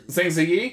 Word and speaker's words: Zhang Ziyi: Zhang Ziyi? – Yes.